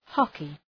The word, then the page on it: hockey: ‘hɒkı